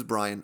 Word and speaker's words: Bryan: My name is Bryan. My name is Bryan with a Y, B-R-Y-A-N.